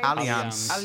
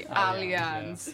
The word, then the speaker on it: Allianz: Aliens Arena, if I'm pronouncing it right. – Allianz. – Okay, like two people correcting me. Allianz. Yeah. So yeah, it's Allianz Arena.